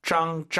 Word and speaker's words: 脏: ZANG이고요. 짱, 짱 세게 발음하시면 ZH 같아요. 선생님처럼 하세요. 짱 들어볼게요. – 짱. – 이렇게 약간 한글로 표기 안 되는 지읒이에요. 짱 이렇게 발음하시면 됩니다.